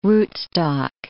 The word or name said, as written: rootstock